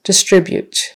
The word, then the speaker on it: distribute: Distribute. Distribute. Second syllable stress. Distribute.